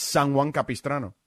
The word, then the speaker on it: San Juan Capistrano: It's San Juan Capistrano.